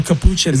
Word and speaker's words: capuchin: What kind of monkey you say was capuchin? – A capuchin.